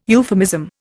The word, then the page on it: euphemism: Indian